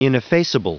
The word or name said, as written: ineffaceable